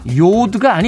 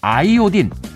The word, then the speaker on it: 아이오딘: 그 결과 요드가 아닌 아이오딘, 부탄이 아닌 부테인이 교과서에 실렸기 때문이죠.